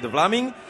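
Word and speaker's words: De Vlaeminck: je prononce de Vlaminck pour les Français, mais c'est de Vlaming, à Museu.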